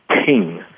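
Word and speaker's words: ting (聽): And it's based on the traditional Chinese symbol of listening, and it's pronounced ting.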